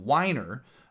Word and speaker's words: Winer: And then the Winer, spelled like wine, like the drink.